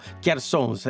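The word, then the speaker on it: cjarsons: per cui pian piano insomma sto Charsons. E poi, insomma, esatto. Poi c'è anche l'accento, la O deve essere chiusa, è divertente la fonetica.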